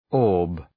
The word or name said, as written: orb